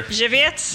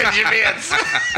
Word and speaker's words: Żywiec: I remember when you worked at PRL, there was a beer I'd get, and I could never pronounce it, and I just would ask you, because I love how you pronounced it. You remember that? It was a Polish beer. – Żywiec? – That's it, Żywiec. And it's not spelled like that at all.